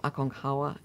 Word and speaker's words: Aconcagua: Well, it's spelled Aconcagua. – Cagua, which is how I would have, yes. – Yes, but I think the locals, it's more Aconcagua.